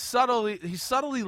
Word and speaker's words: subtly: Damn, B is silent. He's subtly letting you know that he got more opportunities.